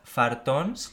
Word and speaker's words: fartons: Han sido, eh, es que me da cosa decirlo, fartons, lo he dicho bien.